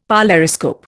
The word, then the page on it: polariscope: Indian